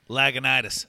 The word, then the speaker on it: Lagunitas: I probably didn't even say the name right, like everybody else. – Lagunitas. – Lagunitas, yeah. – Lagunitas.